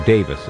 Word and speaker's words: Davies: Now, his name is spelled D-A-V-I-E-S, so most Americans tend to pronounce it Davies, even though Davis is the common British pronunciation.